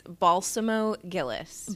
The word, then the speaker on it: Balsamo-Gillis: Yes, it's Balsamo-Gillis.